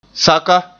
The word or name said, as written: saka